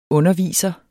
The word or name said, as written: underviser